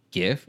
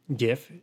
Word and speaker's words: GIF: So most people take the hard G sound like in the word guess from the word graphics in graphics interchange format and pronounce the word GIF. A smaller group of people use a soft G like in the word gibberish because grammatically a G followed by the vowel I tends to be pronounced with a soft G. GIF, GIF.